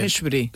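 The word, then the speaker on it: Mejbri: Eh, Meshbri. Sí, eh, está buscando, se pronuncia más o menos.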